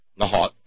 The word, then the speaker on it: Nahuatl: Nahuatl, I don't think we pronounce the L. I've learned that recently. It's kind of glottal.